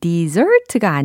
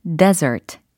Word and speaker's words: desert: 어, dessert가 아니라 desert. desert. 라고 해야 사막에 해당하는 어, 발음이 되는 거죠. 철자도 D-E-S-E-R-T 라고 해서 이제 desert, 사막에 해당하는 단어가 됩니다.